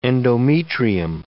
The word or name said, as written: endometrium